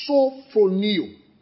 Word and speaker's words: sophroneo: Is the word sophroneo. S-O-P-H-R-O-N-E-O. Sophroneo. S-O-P-H-R-O-N-E-O. S-O-P-H-R-O-N-E-O.